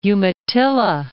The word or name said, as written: Umatilla